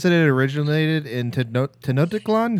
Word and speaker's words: Tenochtitlan: Tenochtitlan.